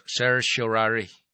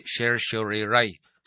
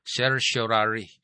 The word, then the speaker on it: certiorari: Ang kining certiorari mao na sakto nga pag-pronounce, certiorari. Oh. Dili certiorari, certiorari. Mao na sakto nga pag-pronounce ana.